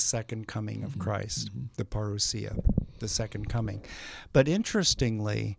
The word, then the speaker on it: parousia: parousia. It's a Greek word. – How do you spell it? – P-A-R-O-U-S-I-A, parousia.